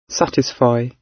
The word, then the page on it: satisfy: British English